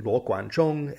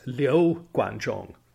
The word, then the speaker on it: Luo Guanzhong: By the way, I was listening to that old Three Kingdoms episode and I realized I was pronouncing Luo Guanzhong as Liu Guanzhong.